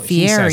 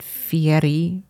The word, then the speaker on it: Fieri: Yeah, it's so Fieri, he says his name. – But he pronounces it Fieri with like rolling the R, like the Italian pronunciation.